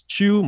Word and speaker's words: Chu: Type in my name, Chris Chu. My last name is spelled C-H-U. Or you could find me on Instagram, who's Chris Chu, W-H-O-S, Chris Chu, C-H-U.